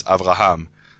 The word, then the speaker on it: Avraham: Okay, so the Hebrew name for Abraham is Avraham. So is it Avraham or Avraham? Is it syllable or syllable?